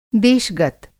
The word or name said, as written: देश-गत